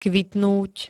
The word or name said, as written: kvitnúť